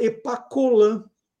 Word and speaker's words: Epacholan: Meu pai falou: olha, embora se escrevesse Epacholan, é, e ele falou que lembra direitinho, né? Porque era um, era um produto que se falava demais, se falava Epacolan, né?